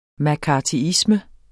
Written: mccarthyisme

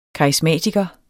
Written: karisma

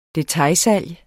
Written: DETAILSALG

